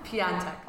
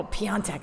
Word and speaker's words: Piontek: How do I say that? – Piontek. – Oh, Piontek. I totally butchered that.